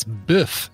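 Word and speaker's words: bœuf: No, you got to pronounce it's biff. It's B with that... The O and the E that are jammed together to make one letter.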